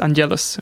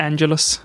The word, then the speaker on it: Angelus: Jag, jag hörde att eh, det ska uttalas Angelus. Angelus. – Och inte Angelus.